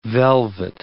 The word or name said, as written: velvet